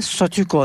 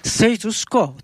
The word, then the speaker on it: Status Quo: Aslında Statüko diyoruz ama e, Amerikalı e, oldukları için e, doğru telaffuzu kuşkusuz status quo tabii. Ama biz bu kelimenin e, Türkçe'de de yerleşmiş ve kullanılan bir kelime olduğu için ta başından beri biliyorsunuz gruba status quo yerine statüko diyoruz.